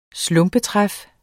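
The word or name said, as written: SLUMPETRÆF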